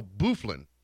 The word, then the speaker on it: Byfuglien: Dustin Byfuglien. Apparently, he's a hockey player for. Bufflin. Okay, I've completely mispronounced it. Dustin Bufflin, but it's spelled B-Y-F-U-G-L-I-E-N.